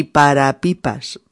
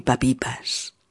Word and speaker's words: para pipas: Sería no tengo ni para pipas, pero tal como se pronuncia, en realidad es no tengo ni papipas. También me río por no llorar.